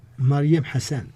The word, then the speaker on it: Mariam Hassan: ¿Lo pronuncia bien? – Más o menos. – sí, me, me temo. Pronúncialo tú, a ver. ¿Cómo se llama ella? – Mariam Hassan. – Hassan. Nasalizan ustedes como los indios mexicanos.